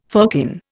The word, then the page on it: firkin: Indian